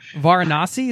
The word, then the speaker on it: Varanasi: Of all the travelers in Varanasi, is that how I pronounce that city? – Yeah, correct.